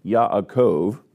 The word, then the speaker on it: Yaakov: The name Yaakov, that's the way it's pronounced in Hebrew, is actually a combination of a Hebrew letter with the word Ekev.